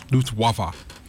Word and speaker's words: Luftwaffe: In 1942, the Luftwaffe, right? Is that pronounced correct? – Luftwaffe.